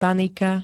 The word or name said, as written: panika